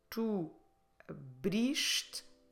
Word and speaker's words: abriste: So, you informal, you opened, tu abriste, is brish, is the stressed syllable, the second to last one.